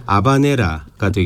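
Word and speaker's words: habanera: 이 스페인식 원어 발음으로는 H가 묵음이니까 아바네라가 되겠습니다만 그렇죠.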